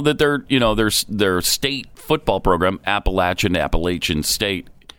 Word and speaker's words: Appalachian: Appalachian American. I used to pronounce it Appalachian, but I think everybody calls it Appalachian.